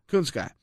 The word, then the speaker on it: Kunitskaya: I did think, and I don't know how to sugarcoat it, that this opponent, warm body, as you put it, how do I say it? – Kunitskaya. Yuna Kunitskaya.